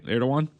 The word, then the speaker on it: Erdogan: I found a hilarious clip, actually, from about 10 years ago of Putin and, how do you pronounce the president of Turkey's name, Matt? I know it's spelled Erdogan. Erdogan? Is that right? Erdogan? – Correct.